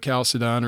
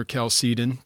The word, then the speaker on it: Chalcedon: Certainly later on, the Council of Chalcedon or Chalcedon, depending on your preference for how to pronounce that,